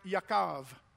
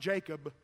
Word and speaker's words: Yaakov: In Hebrew, it's pronounced Yaakov. It's not Jacob. It's Yaakov.